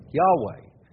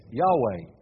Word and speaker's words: Yahweh: And God's name is revealed in Scripture, pronounced pretty much this way, Yahweh. Four Hebrew letters corresponding to our Y, our H, our V or W, and H, Yahweh.